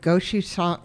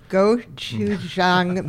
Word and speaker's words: gochujang: I would like to know what gochujang, how do you say that? Gochujang mayo. What is that? – Okay. Gochujang is a fermented chili paste.